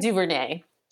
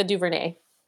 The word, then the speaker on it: DuVernay: DuVernay. – DuVernay? – Yep, Ava DuVernay.